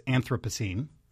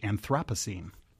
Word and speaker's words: Anthropocene: Well, it's Anthropocene. Or if you're British, Anthropocene. So those are the three contenders.